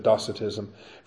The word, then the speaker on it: Docetism: Docetism, or some people pronounce it Docetism, from the Greek word dokeo, I seem.